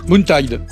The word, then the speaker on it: Moontide: Moontide. – En plus, vous le prononcez bien.